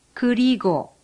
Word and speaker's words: 그리고: A U with a bar on top is pronounced like the U in put. However, your lips should not be rounded. For example, 그리고.